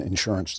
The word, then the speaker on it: insurance: Who's going to explain to Jeremy that the accent in insurance does not go on the first syllable?